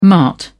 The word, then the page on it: mart: British English